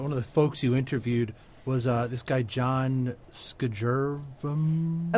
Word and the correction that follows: Skjervem: Oh, Shervem.